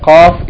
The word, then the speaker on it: Qaf: Yani, the Kaf is pronounced Ka, and the Qaf is pronounced Qa.